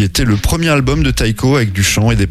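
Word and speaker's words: Tycho: Taiko dans Novorama, ou Ticho hein, si vous voulez le prononcer à la française, avec son album qui s'appelle Simulcast.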